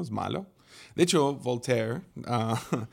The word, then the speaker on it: Voltaire: Lo había puesto en Google y puesto, ¿cómo se dice en español? Voltaire o Voltaire. V-O-L-T-A-I-R-E.